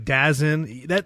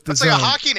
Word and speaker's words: DAZN: Usually it's like DAZN. That seems to be the most proper. It's like, no, it's DAZN.